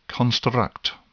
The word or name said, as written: construct